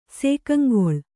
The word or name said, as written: ಸೇಕಂಗೊಳ್